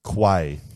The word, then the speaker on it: Quay: Key. – Yep. As in Elizabeth Key. – Literally as Quay, not Key. How it should be pronounced.